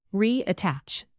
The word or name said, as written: reattach